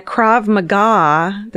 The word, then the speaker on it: Krav Maga: One day they brought in a Krav Maga.